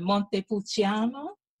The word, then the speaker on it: Montepulciano: And so this one is a, how do you pronounce it, Montepulciano.